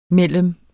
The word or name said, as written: mellem